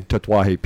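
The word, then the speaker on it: Tatuaje: You know, some of it's just about where does the emphasis go, you know? So, yeah. But Tetuaje, we've got to put the emphasis on the right syllable.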